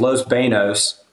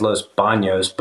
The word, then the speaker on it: Los Banos: And then it was Los Banos, which you could also say is Los Baños, but that's not the correct way to say it.